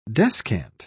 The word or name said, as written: descant